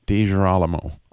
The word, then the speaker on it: DiGirolamo: DiGirolamo.